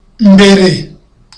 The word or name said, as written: mbere